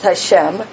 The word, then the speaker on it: Hashem: I believe it's Hashem. Hashem is how they pronounce it.